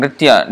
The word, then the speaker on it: nritya: Gita, this nritya is to be pronounced like that. Nritya. So this is a little bit difficult, actually one of the most difficult sounds in Sanskrit, when N followed by R. Nritya.